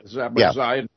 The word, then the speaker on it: Zaporizhia: what is it, Zaporizhia, how you pronounce that, nuclear plant over there. – Zaporizhia nuclear plant.